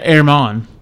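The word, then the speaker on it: Germán: I always look at his, how do you pronounce it? – Yeah, Herman. – Oh, I had no shot then. Yeah, Herman.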